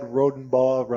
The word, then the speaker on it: Rodenbaugh: Bravo, Jim Bogan, Lucy and Richie Myers, and Suzanne Rodenbaugh rhymes with law.